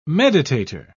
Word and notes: meditator: British English

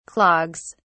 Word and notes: clogs: British English

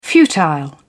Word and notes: futile: British English

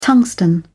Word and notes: tungsten: British English

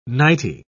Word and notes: nighty: British English

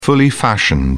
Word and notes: fully fashioned: British English

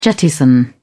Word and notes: jettison: British English

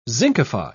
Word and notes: zincify: British English

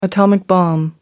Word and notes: atomic bomb: British English

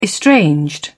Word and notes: estranged: British English

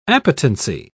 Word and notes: appetency: British English

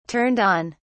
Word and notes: turned on: British English

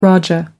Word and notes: rajah: American English

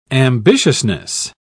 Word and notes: ambitiousness: British English

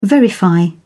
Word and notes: verify: British English

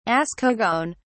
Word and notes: ascogone: British English

